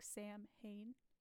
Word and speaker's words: Samhain: or it's pronounced like Sam Hain is how it's spelled, sorry, but it's pronounced Samhain, so everyone knows.